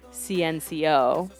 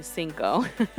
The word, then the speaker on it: CNCO: I thought I brought this group before because I remember Veto correcting me that it isn't CNCO, but it's actually Cinco. The name is spelled CNCO.